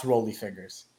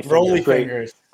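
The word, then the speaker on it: Rollie Fingers: My favorite card in this auction is the 1969 Topps Rolly Fingers. – Rolly Fingers.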